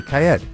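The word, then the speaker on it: Kyed: Kaid. That's how you say it? – Yeah. Or Kaid. – It's K-Y-E-D. Kaid.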